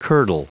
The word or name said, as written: curdle